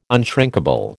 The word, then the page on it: unshrinkable: American English